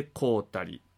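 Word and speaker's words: 神足: 神の足と書いて小谷と読みます、まあ、難読の一つですよね。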